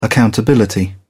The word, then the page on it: accountability: British English